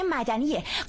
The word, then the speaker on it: مدنی: شما گفتین قانون مدنی ولی تلفظ درستش قانون مدنیه.